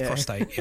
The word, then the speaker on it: prostate: Oh, yeah, you were saying prostrate. – You said it's prostate. – Yeah, prostate.